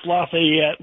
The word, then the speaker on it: Lafayette: Yeah, it's Lafayette, like the general from France who helped us win. – The Marquis. Marquis de Lafayette, yes. Yes. – Yeah, Lafayette is who it is.